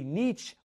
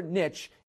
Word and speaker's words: niche: The reality is, I think you can probably be correct if you say niche or niche. Either one is good. I'm sticking to niche, and that is what I'm going to do here because the Grammar Girl said I could.